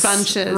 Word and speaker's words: Funches: Funches. – Funches.